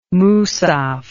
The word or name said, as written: musaf